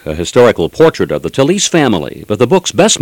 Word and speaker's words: Talese: The real name is pronounced Talese. T-A-L-E-S-E is Talese. And Gay is from Gaetano.